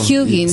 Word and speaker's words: Huginn: No sé, la pronunciación es Hugin Mooning. – ¿Es en inglés? – No. It's a Norse word. – Yes, it's the mitología nórdica. – Ah, so, Huginn. Sí, Huginn and Mjuginn.